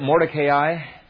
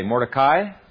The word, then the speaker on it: Mordecai: Do you know why it is that Mordecai... And by the way, you can pronounce it either way, Mordecai or Mordecai.